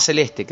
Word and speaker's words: Celeste: pero no volvió se llamaba Celeste, que se pronunciaba con ch, como violonchelo.